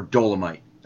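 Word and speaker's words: Dolemite: So they have made a movie about the blaxploitation film from the 1970s called Dolmite, right? Or Dolomite, as they pronounce it.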